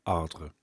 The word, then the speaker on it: Ardres: I could never do that RE sound at the end of a word in French. Anyway, there you go, Ardres.